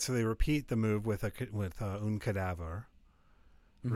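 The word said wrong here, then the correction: cadavre: And again, I know I just said that wrong. In French. Sorry. It's cadaver.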